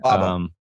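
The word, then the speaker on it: Baba O'Riley: Baba, am I saying it right? Baba O'Reilly? – Baba. – Baba O'Reilly. Baba. Okay. I was thinking it was Barbara and they left out the R, but Baba Booey.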